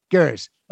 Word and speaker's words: Gers: Gers.